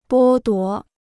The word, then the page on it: 剥夺: bō duó